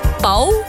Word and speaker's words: Pau: Van Pau naar Gavarnie-Gèdre. Zeg ik het goed? Gavarnie-Gèdre? – Ja, het is dus Pau.